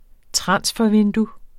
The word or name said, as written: transfervindue